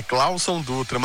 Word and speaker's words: Cláudio Dutra: Ai, gente, olha, a gente tá rindo muito da maneira como vocês estão pronunciando o meu nome, tá? É Cláudio Dutra, mas eu nem julgo o Ricardo Garcia, porque é um nome complicado mesmo, né?